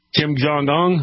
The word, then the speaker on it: Kim Jong-un: Yeah, I thought it was amazing when he broke that relationship with Kim Jong-un.